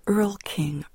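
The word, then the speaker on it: Erlkönig: In my Danubian, Schwabian family, there's a story called the Ölkönig, and it means the Earl King. Earl is spelled E-R-L, and then König is K-O-N-I-G, and it was pronounced in English, anglicized Earl King.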